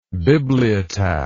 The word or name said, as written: bibliotaphic